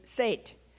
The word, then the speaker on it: Set: Well, the Egyptians worshipped the Nile god, Hapi, H-A-P-I. They also worshipped the crocodiles in the Nile, and his name was Set, S-E-T, but they pronounced E long A.